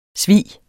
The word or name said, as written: svig